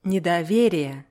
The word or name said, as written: nedowerije